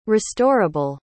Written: restorable

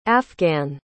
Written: afghan

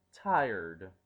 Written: tired

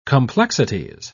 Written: complexities

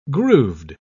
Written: grooved

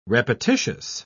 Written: repetitious

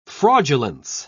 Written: fraudulence

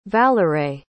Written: valarae

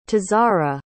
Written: tazara

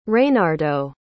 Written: reynardo

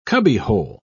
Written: cubbyhole

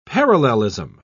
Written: parallelism

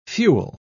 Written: fuel

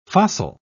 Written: fossil

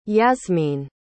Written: Yasmine

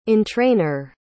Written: entrainer

